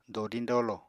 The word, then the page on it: dodiñ-dolo: apatani